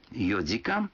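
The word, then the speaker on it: йодикан (四時間): Некоторые цифры в этих случаях произносятся не так, как обычно. 4 часа – это йодикан, 7 часов – ситидикан, а 9 часов – кудикан.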